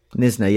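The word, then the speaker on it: Knysna: Because we've got George established in 1811, and we've got, how did you pronounce it? Nisna, yeah, the K is silent.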